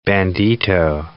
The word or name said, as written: bandito